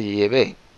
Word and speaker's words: Yahvé: Yo creo que la pronunciación correcta es Yahvé.